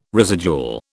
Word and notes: residual: British English

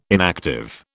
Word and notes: inactive: British English

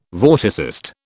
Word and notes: vorticist: British English

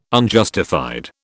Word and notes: Unjustified: British English

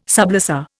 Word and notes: sublessor: Indian

